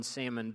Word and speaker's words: salmon: we might as well begin pronouncing the L in salmon because nothing matters anymore.